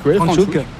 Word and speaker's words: Franschhoek: Franschhoek, je ne garantis pas la prononciation. – Franschhoek.